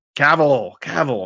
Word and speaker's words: Cavill: Savell? I always fuck that dude's name up. – Cavill, of course.